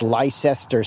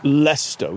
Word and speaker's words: Leicester: You know, the Americans, they come over and they say, where is Leicester Square? That's what Americans sound like. And you go, it's pronounced Leicester. We don't say the E-S.